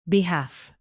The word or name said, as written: behalf